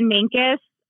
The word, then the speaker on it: Mancos: And is it Mancos, Colorado? How do you say that? – We say it Mancos, but there are a lot of pronunciations for things around here.